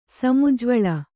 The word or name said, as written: ಸಮುಜ್ಜ್ವಳ